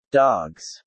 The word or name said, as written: dogs